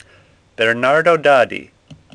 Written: Daddi

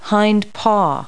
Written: hind paw

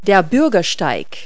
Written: sidewalk

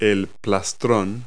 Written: plastron

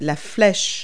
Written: arrow

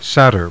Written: satyr

